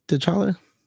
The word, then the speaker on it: T'Challa: And I like the how they did the death, you know, of T'Challa. Is that how you say it? T'Challa? – Yeah.